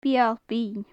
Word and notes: beul binn: gaelic

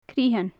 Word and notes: critheann: gaelic

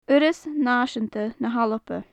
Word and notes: urras naiseanta na h-alba: gaelic

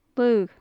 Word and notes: laogh: Gaelic

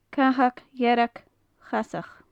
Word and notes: cathag dhearg chasach: gaelic

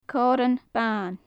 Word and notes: coirean ban: gaelic

